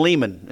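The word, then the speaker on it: Leman: Yeah, I'd forgotten about that It's by Dr. Kevin LeMann and Bill Pentak. – Kevin Lehman is pronounced. Lehman. – Yep. Kevin Lehman. Lehman.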